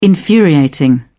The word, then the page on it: infuriating: British English